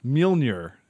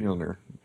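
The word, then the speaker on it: Mjölnir: Yeah, Mjolnir is what it's actually. – Mjolnir.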